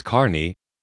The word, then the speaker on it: Kearney: Spelled Kearney, pronounced Carney, The point is that even the most Bell of the Bell rings weren't made by Bell.